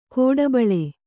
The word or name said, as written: ಗಿಡಬೀಳು